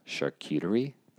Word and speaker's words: charcuterie: Charcuterie.